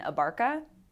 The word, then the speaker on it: Abarca: Abarca, and my last name is spelled A-B-A-R-C-A.